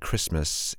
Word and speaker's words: Christmas: It's funny how the T in the word Christmas is silent.